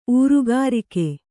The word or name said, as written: ಊರುಗಾರಿಕೆ